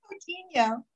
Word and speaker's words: Coutinho: How am I pronouncing it again? – Coutinho. It's a hard last name. That's why I go by Michelle Infinity online. But it's okay. Michelle Cotuia, Coutinho, but you can put the link in the whatever.